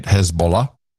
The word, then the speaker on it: Hezbollah: As we've learned how to say it, Hezbollah.